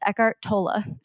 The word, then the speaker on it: Eckhart Tolle: And I first started learning this stuff from Eckhart Tolle, which apparently that's how you pronounce his name. Everyone pronounces his name a different way, but I Googled it before this episode, and it's Eckhart Tolle.